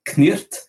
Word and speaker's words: cniht: ça s'écrit C-N-I-H-T, en le prononçant en anglais de l'époque, ça donnerait Knirt.